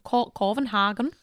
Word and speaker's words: Copenhagen: Oh, is that how they say it? – Yeah, Copenhagen. – Oh, really? Mm-hmm. I didn't know that. – Yeah. Yeah. I enjoyed it. – So they don't say Copenhagen? – No, not really. Oh. They have two more vowels than we do, and they pronounce things in a really different way.